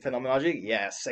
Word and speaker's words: phenomenology: Phenomenology? Yes, exactly.